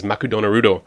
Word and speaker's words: Makudonarudo: which in the Japanese pronunciation, McDonald's is Makudonarudo.